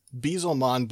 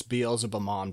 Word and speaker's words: Beelzemon: It's Beelzemon, but in Japanese it's Beelzebumon because it's, you know, Beelzebub is what they're doing.